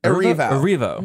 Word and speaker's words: Erivo: How do you pronounce Erivo? – Erivo. Mm-hmm.